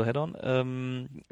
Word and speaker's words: Head-On: Hedon, nee, das müssten wir eigentlich bei der GameStar mal anrufen und sagen, nee, Daniel, so wird es nicht ausgesprochen. – Nein. – Also Head-On, was mittlerweile aus meiner Sicht ein sehr unterhaltsames Spielchen ist.